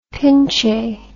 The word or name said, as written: pinche